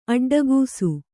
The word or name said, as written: ಅಡ್ಡಗಿಸು